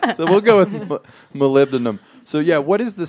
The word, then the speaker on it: molybdenum: I mean, and what people should know is that that mountain is full of molybdenum. Did I say that right? – You nailed it.